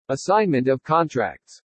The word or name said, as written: ASSIGNMENT OF CONTRACTS